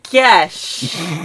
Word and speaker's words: gesh: On the pronunciation of gesh. It rhymes with the word mesh.